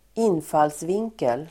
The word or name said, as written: infallsvinkel